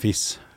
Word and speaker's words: FIS: Vi uttaler vel det FIS, med, med trykk på S-en. ja, det er bra.